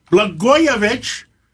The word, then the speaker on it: Blagojevich: And we have former governor Rod Blagojevich.